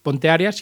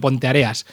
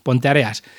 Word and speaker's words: Ponteareas: Son de Arrúa, son de Ponteareas, que resulta que lo de Areas es por arena, no por áreas. Por eso la tilde va en otro lado y no es Ponteareas, sino Ponteareas. Que los programas anteriores que he puesto a Son de Arrúa lo he dicho mal y me lo han corregido, así que lo dejo patente.